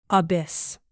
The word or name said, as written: abyss